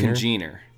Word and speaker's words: Congener: Congener? – Congener.